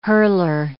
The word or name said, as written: hurler